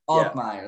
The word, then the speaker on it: Altmire: Altmire.